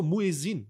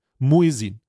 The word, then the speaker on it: Muezzin: Ach so, Muezzin. So spricht man das aus. Okay, danke, Chat. Muezzin.